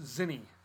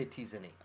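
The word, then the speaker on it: Tzinni: No, it's just Zinni. The T is silent. – I don't care. I say T Zinni.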